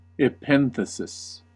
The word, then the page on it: epenthesis: American English